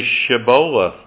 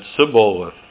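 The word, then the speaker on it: Shibboleth: If he said no, then they would say to him, "Say Shiboleth." But he said Siboleth, for he could not pronounce it correctly.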